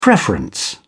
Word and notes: preference: British English

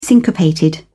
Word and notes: syncopated: British English

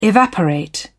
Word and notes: evaporate: British English

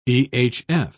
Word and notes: ehf: British English

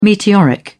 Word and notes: meteoric: British English

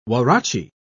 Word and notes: huarache: British English

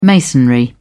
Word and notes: masonry: British English

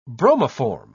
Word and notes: bromoform: British English

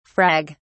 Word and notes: frag: British English

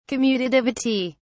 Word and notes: commutativity: British English

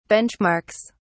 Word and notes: benchmarks: British English

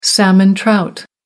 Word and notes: salmon trout: American English

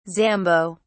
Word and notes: zambo: British English

